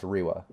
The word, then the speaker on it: Riva: Yeah, it's spelled with a V, but pronounced Riwa, I think, right? – Yeah.